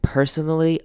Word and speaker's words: personally: Then someone starts swearing and personally attacking. Personally, 这边也要注意，注意它重音在第一个音节 personally.